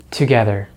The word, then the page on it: together: American English